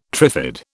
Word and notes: trifid: British English

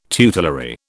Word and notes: tutelary: British English

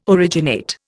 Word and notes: originate: Indian

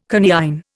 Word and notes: coniine: Indian

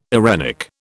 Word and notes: eirenic: British English

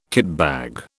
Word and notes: kitbag: British English

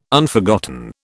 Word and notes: Unforgotten: British English

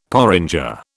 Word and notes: porringer: British English

